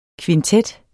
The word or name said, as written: kvintet